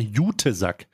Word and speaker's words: Jutesack: Nicht zu, vergessen, nicht zu verwechseln mit einem äh, eine falsche Aussprache. Jutesack. Jutesack. Nicht Hodensack. Ganz wichtig. – Nee, nicht, auch nicht Judensack, sondern Jutesack. Ganz, ganz wichtig in der Aussprache in diesem Zusammenhang.